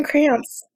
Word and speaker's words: Kranz: Yeah, Fran Kranz.